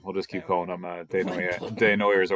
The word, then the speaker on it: Desnoyers: But also, to that matter, Elliot Desnoyers is evidently how you say his name, not Desnoyers, like we were saying.